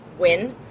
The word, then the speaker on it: Nguyen: N-G-U-Y-E-N. – N-G-U-Y-E-N. – There we go. – Yeah, and it's pronounced, sometimes it's pronounced Wynn. And it's pronounced other ways by other people. – Yeah. – So, but I'm pronouncing it Wynn for that character.